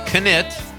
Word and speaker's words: Knitt: Craig Anthony Knitt, I'm saying that right, the K is not silent,